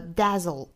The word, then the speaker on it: dazzle: flip a 和 dazzle a、啊、很多同学会把这个 a 发成一个类似于 i 的发音，也就是在发这个音的时候呢，会把它拖长一点，发出一个 a 的发音 i，这样就不对了，应该是 dazzle，